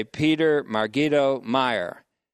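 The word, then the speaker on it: Peder Margido Myhre: Now, this goes to illustrate the point made by another man they quote, named Peter, P-E-D-E-R, Margido Meyer. His name is spelled P-E-D-E-R, middle name M-A-R-G-I-D-O, and last name M-Y-H-R-E. I would say Peter Margido Meyer.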